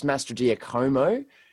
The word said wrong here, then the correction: Mastrogiacomo: but what? – That actually sounds good. – But because it's like Italian, I think it could actually be Mastrogiacomo. – Yeah, it is spelled like Giacomo. – Mastrogiacomo.